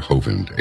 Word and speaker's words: Hovind: That's not the way you pronounce his name. It's actually Hovind, H-O-V-I-N-D.